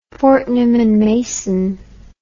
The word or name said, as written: fortnum and mason